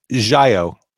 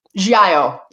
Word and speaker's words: Giallo: And again, it's Giallo. – Giallo.